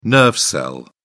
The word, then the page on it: nerve cell: British English